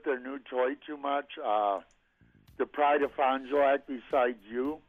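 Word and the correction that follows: Fond du Lac: Yeah, it's pronounced Fondulac.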